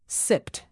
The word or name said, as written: sipped